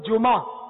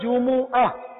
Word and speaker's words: جمعہ: اور اصل تلفظ جو ہے نا وہ ہم جمعہ کہتے ہیں, کوئی جمعہ کوئی جمعہ, تو یہ نہ جمعہ ہے نہ جمعہ ہے, بلکہ قرآن پاک میں جو اس کا تلفظ ہے صحیح تلفظ وہ ہے جمعہ. جیم پر بھی پیش ہے اور میم پر بھی پیش ہے, عین اور ہا جمعہ.